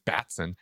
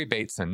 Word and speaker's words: Bateson: I kept calling him Batson. Okay, Gregory Bateson.